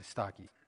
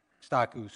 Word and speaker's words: Stachys: Now that is, that's how you'd pronounce it in the Greek, actually, Stachys, Stachus, something like that.